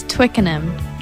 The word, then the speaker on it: Twickenham: It's Twickenham. – Twickenham? – Yeah.